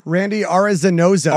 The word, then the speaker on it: Arozarena: How do you say it? – Randy Arozarena. – Arozarena, thank you.